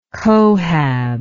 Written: cohab

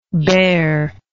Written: baer

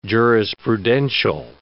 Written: jurisprudential

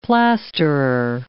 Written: plasterer

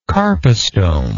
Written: carpostome

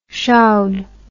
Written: showd

